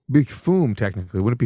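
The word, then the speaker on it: Füm: that is Fume, pronounced F-U-M-E, but spelled F-U-M with umlauts.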